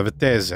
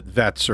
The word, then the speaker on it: Vettese: I was like, how would you pronounce this name? And he's like, oh, that's Vettese. And I'm like, okay, I can try. – Yeah, that sounds bad. – I'm a pretty good mimic. I can try to do that. Because I think originally it was, there's like, in England, a lot of people pronounce it Vets or Vettis or something like that, right?